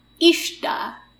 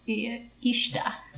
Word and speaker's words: Uisneach: Ishta. – Ishta? – I think. It's spelt U-I-S-N-E-A-C-H, but I listened to someone pronounce it, and I'm pretty sure they said Ishta.